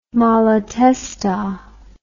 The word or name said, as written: malatesta